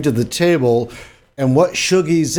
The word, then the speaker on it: Shoogies: It's actually pronounced Shogies. – Shogies. – S-H-O-O-G-I-E-S.